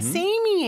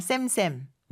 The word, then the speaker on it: same: 근데 우리 쌤쌤이라고 하잖아요. 쌤쌤. 쌤쌤 발음은 안 돼요. Same, same.